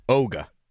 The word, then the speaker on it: Ticonderoga: You went soft G, it's hard G. Oga.